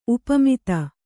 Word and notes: ಉಪಮಿತ: upamita

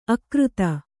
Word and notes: ಅಕೃತ: akřta

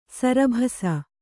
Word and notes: ಸರಭಸ: sarabhasa

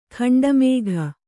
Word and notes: ಖಂಡಮೇಘ: khaṇḍa mēgha

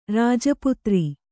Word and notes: ರಾಜಪುತ್ರಿ: Kannada

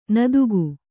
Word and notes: ನೀತಿಕೆ: Kannada

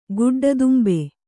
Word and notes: ಗುಡ್ಡದುಂಬೆ: kannada

